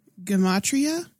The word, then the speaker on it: Gematria: Gematria? Is that how you say it? – Yeah, that thing.